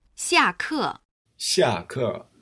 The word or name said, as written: xia ke